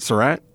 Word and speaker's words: Surat: How do you say that? Surat? – That sounds right.